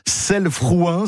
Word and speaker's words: Selles-Frouin: Votre village, j'ai peur d'écorcher le nom de votre commune. Selles-Frouin, c'est comme ça, Guillaume? – Parfaitement dit.